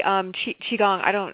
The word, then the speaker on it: Qigong: Yeah, it's actually Qigong. I like how you say it better,